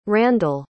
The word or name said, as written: Randal